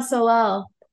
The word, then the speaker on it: Solal: And Martial Solac. – Oh, Martial Solal. – Solal, yeah, on piano and Moutin on bass, coming yesterday.